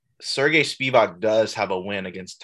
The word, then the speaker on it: Sergey Spivak: Sergey Spivak.